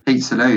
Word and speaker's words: Peterloo: Is it Peterloo? Peterloo, yeah. Peterloo, yep.